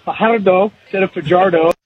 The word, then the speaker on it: Fajardo: Finally, in the chat with Cody Fajardo, Jamie again brought up how we all say Fajardo, but that's not actually how his name is pronounced. And TSN's Luke Wilson alluded to it, I think it was last game, and attempted to say it properly with the rolling of the R's.